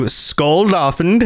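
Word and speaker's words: Skuldafn: So you've got to battle through Skuldolfin Temple. Did I say that right? – Yeah, no, that pronunciation was spot on.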